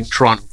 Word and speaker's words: Toronto: And like, also, thank you so much for pronouncing Toronto correctly. – No second tea in that.